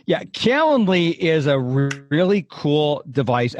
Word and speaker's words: Calendly: You said, do you have something like a Calendly? I don't know how you pronounce it, but Calendly, the calendar? – Calendly. – Calendly. – Yeah.